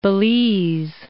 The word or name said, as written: Belize